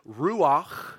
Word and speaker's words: ruach: It's the word ruach.